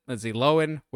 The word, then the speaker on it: Lindsay Lohan: And yes, that's how you pronounce it, Lindsay Lohan, which is just sounds weird to say.